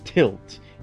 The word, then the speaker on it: TLT: TILT. Even though it's short, like TLT, so yeah, TLT need to lie, yeah. – It's like it is pronounced TILT, you know.